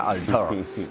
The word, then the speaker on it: Altair: Planeta Prohibido, película del 56, que narra la historia de Morbius, un científico que vive en el planeta Alter 4 con Altair. – Su hija. Altair. Pero se pronuncia en el idioma de Alter, se pronuncia Altair.